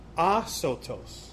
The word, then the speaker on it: asotos: It actually comes from a word in Greek that it's pronounced sotos. If you know anything about Greek, soteriology, it's where we get the idea of salvation. Well, it's asotos. Adding the word a in front of sotos, when you add the word a letter a in front of a word, it negates that word, right?